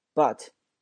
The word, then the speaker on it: but: 首先我们来看这里面发音啊， but, 这个 T 经常不发，